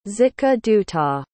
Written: zitkaduta

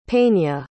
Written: peña

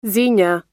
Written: xenia

